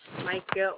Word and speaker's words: Micah: Micah Orr. – Spell it for me. – M-I-C-A-H. My last name's O-R-R.